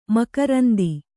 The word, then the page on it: ಮಕರಂದ: makaranda